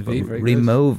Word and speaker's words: Rimowa: We're actually saying it wrong. It's okay. Rimowa. – Okay. – Because it's German. – The W is a V. Rimowa.